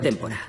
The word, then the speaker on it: Tempora: Uh, Se llama Tempora. T-E-M-P-O-R-A.